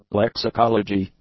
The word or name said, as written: lexicology